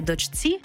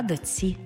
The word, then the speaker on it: дочці: Пишемо «чці», а вимовляємо «ці». Наприклад, не «дочці», а «доці».